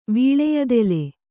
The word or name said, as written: ವೀಳೆಯದೆಲೆ